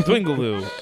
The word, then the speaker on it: Dwingeloo: Dwingeloo.